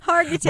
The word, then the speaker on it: Hargitay: Hey, how do you say her name? – Hargitay. – Mariska Hargitay.